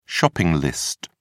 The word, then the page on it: shopping list: British English